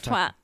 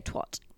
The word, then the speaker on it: twat: What, twat? – Yeah. – You said it the right way. Americans say twat.